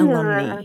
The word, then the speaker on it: alumnae: If they're only female graduates, then you want the feminine ending, which is AE and is pronounced uh, alumni.